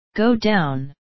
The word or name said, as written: go down